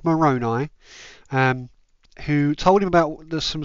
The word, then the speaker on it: Moroni: I think the pronunciation is Moroni, but yeah. Annoying. – Moroni was correct.